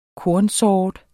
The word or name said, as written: kornsort